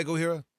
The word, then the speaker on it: Gojira: With it was Gojira. What was the album by Gojira? – Well, it's pronounced Gojira. – With a J.